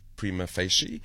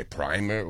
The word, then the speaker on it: prima facie: We're running with prima facie, but the Americans say prima.